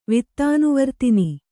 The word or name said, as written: ವಿತ್ತಾನುವರ್ತಿನಿ